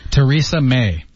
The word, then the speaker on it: Theresa May: Yeah, maybe pronounce Teresa. There are a couple of ways to pronounce that. – 再来一遍啊。 – Theresa May.